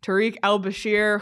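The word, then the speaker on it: Tarik El-Bashir: on the PHNX Sports YouTube channel with a special guest, the Athletics Washington Capitals reporter Tariq El-Bashir, who is the – did I say that correctly, Craig? – Yep.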